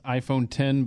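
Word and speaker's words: iPhone X: It's pronounced iPhone 10, but it's it's it got is. the letter X.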